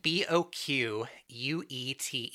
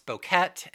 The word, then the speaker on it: Boquete: The city is spelled B O Q U E T E, which some people pronounce Boquet, and the internet also offers the pronunciation of bouquet. – Mm, okay. I'm going to go with Boquet.